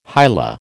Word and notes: hila: American English

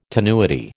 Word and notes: tenuity: American English

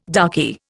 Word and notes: darky: Indian

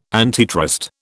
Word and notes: antitrust: British English

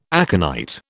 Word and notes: aconite: British English

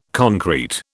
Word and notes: concrete: British English

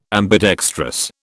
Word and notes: ambidextrous: British English

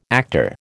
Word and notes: actor: American English

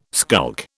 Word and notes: skulk: British English

